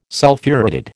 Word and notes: Sulphuretted: American English

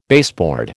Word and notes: baseboard: American English

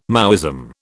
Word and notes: Maoism: British English

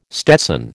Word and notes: stetson: American English

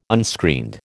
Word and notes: unscreened: American English

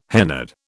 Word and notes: hennaed: British English

